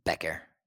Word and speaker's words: Bécquer: It's just Becker. Becker. Like Becker. Like Beckett, but with an R. Becker.